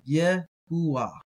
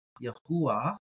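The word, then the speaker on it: Yehuah (יהוה): I guess I should do the pronunciation of that. It would be something like Yehuah. Yehuah, if you wanted to strictly try to pronounce it. So Yehuah, you can hear Jehovah there.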